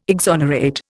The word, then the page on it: exonerate: Indian